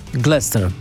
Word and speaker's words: Gloucester: Jak to się wymawia? Gloucester się pisze. – Gloucester.